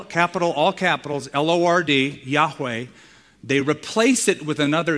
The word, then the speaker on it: Yahweh: And it is perhaps and most probably pronounced Yahweh.